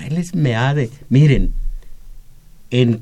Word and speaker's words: Meade: Y en castellano también, meade no se dice mid, se dice meade.